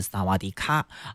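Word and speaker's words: 萨 瓦 迪 卡 (สวัสดีค่ะ): 你一定很熟悉，因为如果加上卡就是萨瓦迪卡，你好，对不对？但其实呢，这个泰语似乎有这个阴性阳性的这个区别，女生讲你好是萨瓦迪卡啊，但是男生呢这个发音要是萨瓦迪 club 我也只会这一句而已了。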